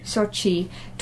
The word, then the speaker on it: Sochi: Sochi.